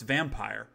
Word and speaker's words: Vampyr: It is spelled V-A-M-P-Y-R, but it is pronounced Vampire.